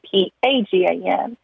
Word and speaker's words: Pagan: And one of the people in the soap opera was named Pagan, P A G A N. And that's how my grandma pronounces my name, is actually Pagan, like the religion.